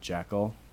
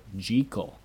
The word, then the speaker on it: Jekyll: You know, Dr. Jekyll and Mr. Hyde, everybody pronounces it Jekyll. – Mm-hmm. – In the original book, the author pronounces it Jekyll. That's how you pronounce it.